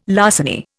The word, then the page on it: larceny: Indian